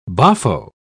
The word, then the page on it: boffo: British English